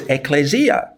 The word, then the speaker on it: ecclesia: She would shout, no, no, no, no, it's ecclesia. Every time I pronounced the Greek word, she shouted out. It was great fun. So it's ecclesia.